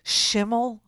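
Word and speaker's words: Schimel: A guy, I think his name is Schimmel, rhymes with Kimmel, but has nothing to do with him.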